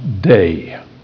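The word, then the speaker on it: day (δεῖ): If we were transposing from Greek to English, it would be D-E-I. In the Greek, it's pronounced day, like D-A-Y.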